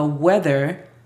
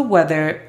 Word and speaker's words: weather: La pronunciación fonética es igual para how, J-A-O, U-E-L para will, D-A para the. Vamos a cambiar la pronunciación fonética en esta, porque de hecho es con la T-H, U-E-T-H-R. Weather. Si no recuerdas, la pronunciación de la TH la hacemos si colocamos nuestra lengua entre los dientes y soplamos.